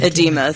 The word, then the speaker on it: edema: Edema? How do you say that? – Edema.